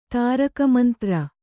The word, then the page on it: ತಾರಕಮಂತ್ರ: tāraka mantra